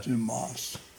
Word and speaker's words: Dumas: Well, it's something about the French, they don't pronounce the S's, you know, it's like Alexander Dumas, not yeah. Dumas.